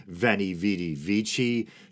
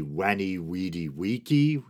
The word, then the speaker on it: veni, vidi, vici: And I know I'm kind of being inconsistent here, because technically in classical Latin, I believe the V should be pronounced as a W, So as ridiculous as it might sound to our modern ears, Julius Caesar's proclamation, I came, I saw, I conquered, veni, vidi, vici, should actually be weni, weedy, weeky.